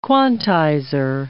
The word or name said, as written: quantizer